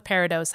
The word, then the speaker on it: peridot: So most jewelers and gemstone collectors don't pronounce the T. We say peridot, following the French.